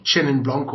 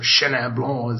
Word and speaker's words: Chenin Blanc: Well, just in general terms, Ian wasn't being disparaging about Chenin Blanc or Chenin Blanc, as the French would pronounce it.